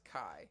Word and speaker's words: Kia: Yeah, Kia, or known as Kai. – Okay, yeah. – The reason why I say Kia first is because it's spelled K-I-A, mm-hmm. but they're known as Kai.